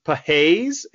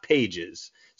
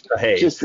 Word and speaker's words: Pages: It's Andy Pages. It's not Pages. So don't sound like an idiot and say Pages if you are out there. It's Pages. What can you tell us about Andy Pages?